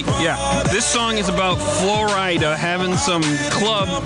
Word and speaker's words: Flo Rida: Flo Rida.